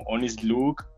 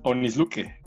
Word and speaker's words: Onis Luque: ¿Qué piensas de que la gente eh, a veces confunda tu nombre y te lo pronuncian de una maneras muy distintas como Onis Luke, Oni Luque o no sé, este, cuando simplemente es Onis Luque.